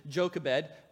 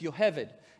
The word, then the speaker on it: Yocheved: and her name is Yocheved. That's the Hebrew pronunciation. In English, we often pronounce it Jochebed, but it's Yocheved.